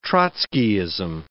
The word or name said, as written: Trotskyism